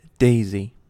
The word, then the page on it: daisy: American English